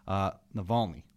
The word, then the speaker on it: Navalny: Alexei, and I hope I'm pronouncing this right, Navalny. Does it sound right? – That sounds about right. – Alexei Navalny is this competitor of Vladimir Putin's,